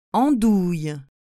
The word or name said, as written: endouille